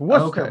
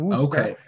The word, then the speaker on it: Worcester: Near Worcester? What area? – No, we're in Boston, and it's Worcester, by the way, not Worcester.